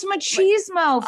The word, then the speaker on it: machismo: I told Eddie the other day, I go, stop being machito. – No, it's machismo. – And he goes, what am I, a Cheeto? I go, stop being mochizo.